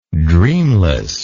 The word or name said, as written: dreamless